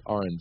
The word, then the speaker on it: R&M: It looks like Arandum, but it's R&M, which is like, you know, just the R for red and the M for Murphy, R&M.